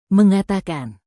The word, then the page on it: mengatakan: indonesian